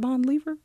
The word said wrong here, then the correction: Bon Iver: Bon Iver? – Oh, Bon Iver?